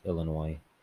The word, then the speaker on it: Illinois: I think it's generally pronounced Illinois. – Yeah. – Yeah, there's a silent.